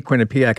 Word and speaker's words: Quinnipiac: I can only say Quinnipiac, I think, twice without totally getting the name wrong. No, you did okay. You got it, Charles.